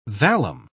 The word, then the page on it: vallum: British English